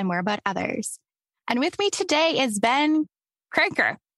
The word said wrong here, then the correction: Crocker: Did I say that right? – Crocker. – Crocker. I'm sorry.